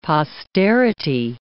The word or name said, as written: posterity